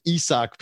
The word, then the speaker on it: Isaac: I know you wrote about Isaac Pitbull Cruz, Isak, the Hispanic Mexican pronunciation, Isak Pitbull Cruz, with his win over Gamboa on that card.